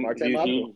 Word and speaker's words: Marte Mapu: I saw I'm going to probably pronounce his name wrong, Marte Mapu. – You got it. Marte Mapu.